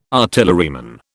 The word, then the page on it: artilleryman: British English